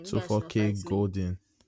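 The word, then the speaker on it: 24kGoldn: 24K Golden.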